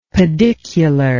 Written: pedicular

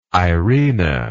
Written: irina

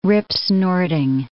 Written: ripsnorting

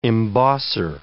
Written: embosser

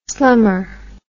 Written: slummer

